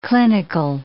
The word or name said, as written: clinical